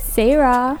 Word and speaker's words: Sarah: Yeah, and I go by the name Sarah. Should I spell it for you guys? – Yeah. – Oh, okay. It goes S to the A to the R to the A to the H. It's Sarah.